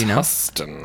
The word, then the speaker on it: Huston: Is it Danny Huston or Danny Houston? – I think it's Huston. – I think it's Huston, too. That's how it's spelled. But then it's... – I feel like Houston would be...